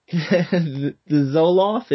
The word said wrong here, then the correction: Zolar: Is that what how you say it? – Zolar. – Oh, Zolar.